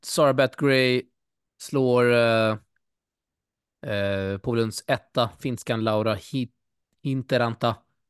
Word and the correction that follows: Hietaranta: Två raka set. – Hietaranta. – Hietaranta, oklart uttal.